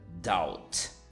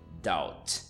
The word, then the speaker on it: doubt: A segunda delas, number two, que eu acho que muita gente, muita gente fala errado, é a palavra doubt. Doubt. D-O-U-B-T. Doubt. Uh, bom, a pronúncia é essa, não se fala doubt, por mais que tenha um B ali.